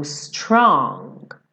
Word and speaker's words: strong: Então, for one so small, you seem so strong. Strong. Tem um acabamento muito leve desse G no final. Strong.